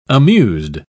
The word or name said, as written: amused